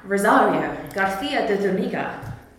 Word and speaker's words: Rosario Garcia de Zuniga: Now, Rosario, Garcia de Zuniga.